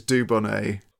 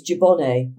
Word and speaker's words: Dubonnet: I pronounce it like Lou Reed pronounces it, which is Dubonnet. – Because in Liverpool, it was Dubonnet.